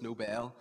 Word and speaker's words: Nobel: It's not Nobel, it's Nobel.